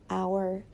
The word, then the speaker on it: hour: So these words begin with the letter H, but the H is silent. So the word is actually pronounced with the vowel sound, hour, air.